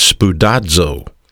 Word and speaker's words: spoudazo: The word is pronounced in the original Greek morphology as spoudazo. That's the Greek word study in the English. Spoudazo. S-P-O-U-D-A-Z-O.